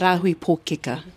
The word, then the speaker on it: Rāhui Pōkeka: Rāhui Pōkeka.